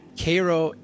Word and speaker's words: Cairo: And it's spelled Cairo as in Cairo, Egypt, but it's like Cairo, Cairo, Illinois.